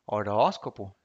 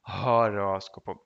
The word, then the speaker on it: horóscopo: Horóscopo se escreve com H no começo, mas esse H não é pronunciado. Então não é horóscopo. Hum, hum. É horóscopo.